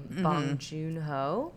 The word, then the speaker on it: Bong Joon-ho: So Parasite is the new movie from Bong Joon-ho. Is that how you say it? – Yes.